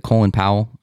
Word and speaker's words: Colin Powell: Former Secretary of State, is it Colin Powell? – Yes.